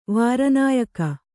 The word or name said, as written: ವಾರನಾಯಕ